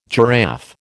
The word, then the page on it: giraffe: American English